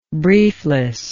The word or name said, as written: briefless